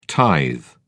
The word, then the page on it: tithe: British English